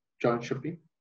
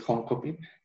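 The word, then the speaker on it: Jönköping: resido en, en, un lugar que se llama eh, John Shopping. Como lo pronunciamos en español es Honkoping, pero el sueco es, es en, Shopping y está ubicado ah,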